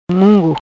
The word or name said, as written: Mungo